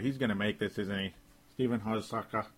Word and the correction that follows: Hauschka: is going to make this. Stephen Hauschka, excuse me, Stephen Hauschka. I don't know why I'm mispronouncing it so bad. I probably still am butchering it.